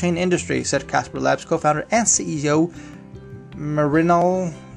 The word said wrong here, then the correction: Mrinal: How do you say that? Mer. M R I N A L. Mrinal, Mrinal, Mrinal Manohar, Mrinal Manohar.